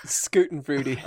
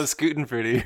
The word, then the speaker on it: Schadenfreude: Don't worry, we'll put the link to how to pronounce that word in the show notes. – Schadenfreude. – Oh, Schadenfreude, you're right.